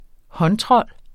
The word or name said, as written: håndtrold